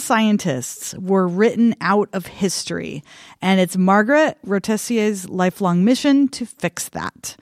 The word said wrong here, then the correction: Margaret Rossiter: So this is in Smithsonian Magazine, has this article about, I'm sorry, I mispronounced her name. It's Margaret Rossiter.